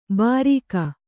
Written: ಭಾರಿಕ

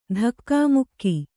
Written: ಧಕ್ಕೆಮುಕ್ಕೆ

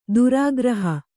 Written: ಧಾರಾಗೃಹ

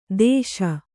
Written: ದೆಶೆ

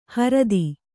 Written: ಹೊರ್ತು

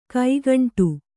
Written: ಕೈಗುಂಟೆ

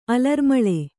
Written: ಅಲರ್ಮಾಲೆ

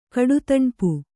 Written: ಕಡುದಣ್ಪು

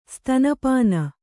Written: ಸ್ತನಪಾನ

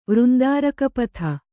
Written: ವೃಂದಾರಕಪಥ

